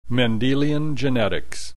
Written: Mendelian genetics

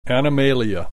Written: Animalia

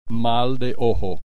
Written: mal de ojo

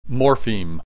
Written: morpheme

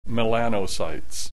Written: melanocytes

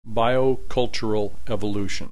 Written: biocultural evolution

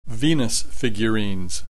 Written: Venus figurines

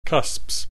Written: cusps

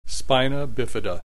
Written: spina bifida

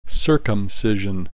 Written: circumcision

